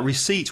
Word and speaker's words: receipt: And another thing about receipt, when you see it, the word, it's R-E-C-E-I-P-T. The pronunciation has nothing to do with the spelling. The yeah. P is silent and it's receipt. Receipt.